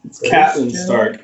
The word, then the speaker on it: Catelyn: It's Catelyn Stark, also, not Caitlyn Stark. It's Catelyn. – Yeah, whatever. – At least that's how it's pronounced in the show. Who knows how it was meant to be pronounced in the book? – It's spelled C-A-T-E.